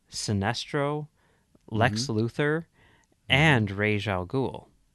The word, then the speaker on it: Ra's al Ghul: If you want to pronounce it like Christopher Nolan does, but if you want to pronounce it the correct way, as established, I think prior to the animated series, but on the animated series, it's Ra's al Ghul.